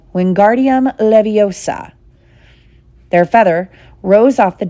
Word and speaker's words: Wingardium Leviosa: Wingardium Leviosa, he shouted, waving his long arms like a windmill. You're saying it wrong, Harry heard Hermione snap. It's Wingardium Leviosa. Make the gar nice and long.